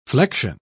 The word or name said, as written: flection